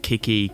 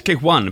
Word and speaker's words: KiCk i: We had a bit of debate yesterday about how that should actually be pronounced. Kiki, Kick I. And it's Kick One, apparently, because there are more kicks coming later.